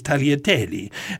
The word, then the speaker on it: tagliatelle: And so it was with great pleasure I was able to boom back that Tagliatelle, the G is silent, it is Tagliatelle, and it's Cinzano, not Cinzano.